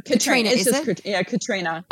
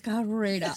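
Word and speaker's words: Caitríona: And I can't pronounce her name, Katriona Balfe. – Katrina. – Is that the Gaelic spelling, is it? – It's the Gaelic spelling.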